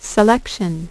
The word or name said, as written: selection